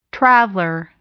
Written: traveler

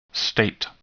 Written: state

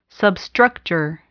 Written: substructure

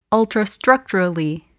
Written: ultrastructurally

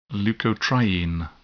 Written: leukotriene

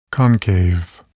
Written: concave

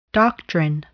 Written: doctrine

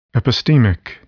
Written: epistemic

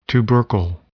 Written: tubercle

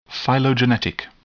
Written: phylogenetic

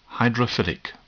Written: hydrophilic

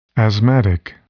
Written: asthmatic

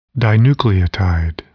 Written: dinucleotide

